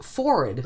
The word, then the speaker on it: forehead: It used to be, though, and this is something that disturbed me as a little kid, it used to be pronounced forward.